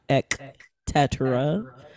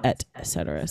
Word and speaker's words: et cetera: yes, so it's definitely ETC, et cetera. That's how it is spelled, not ek tetra, but no, it's et cetera.